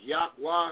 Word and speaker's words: Yahweh: And like I said, again, we're here to, we're doing a series called A Due Order of YHWH, proper name YHWH is Yahweh, how you pronounce it.